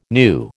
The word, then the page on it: new: American English